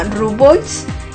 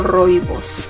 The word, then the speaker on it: rooibos: Hay un té que se llama Rooibos. Los rooibos, así se pronuncia, o rooibos, es R-O-O-I-B-O-S, rooibos.